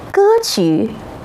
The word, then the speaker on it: 歌曲: 那当三声曲这个读音时，还能组成什么词语呢？有歌曲、曲调。看来和乐曲相关的时候，它读作三声。